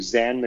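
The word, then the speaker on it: Zahn: And I got to say, I'm going to give a big shout out here to Zan McLarnon showing up in that movie. – Zach McLarnon. – Okay, it's Z-A-H-N on IMDb. So I just mispronounced it.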